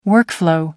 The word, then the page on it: workflow: American English